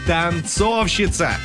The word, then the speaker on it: танцовщица: Танцовщица. О, как я танцую. Вот, на «о» ударение.